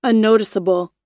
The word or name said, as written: unnoticeable